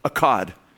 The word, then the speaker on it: Echad: The word that's used is echad. E C H A D is how you would transliterate that from the Hebrew. Echad.